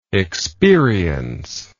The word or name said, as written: experienceless